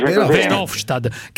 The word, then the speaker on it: Verhofstadt: Ma io lo pronuncio bene perché Verhofstadt si dice Verhofstadt.